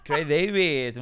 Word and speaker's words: Craig David: Craig David. – Muy bien. ¿Cómo lo he pronunciado? Craig David. Craig David, muy bien.